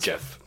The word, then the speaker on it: Geoff: Jeff. – Is it Jeff? – Jeff Johns. No, that reads as Geoff. – It's pronounced Geoff. – It looks like Geoff, but it goes by Jeff. – How do you pronounce that if there's an E-R-Y at the end of it?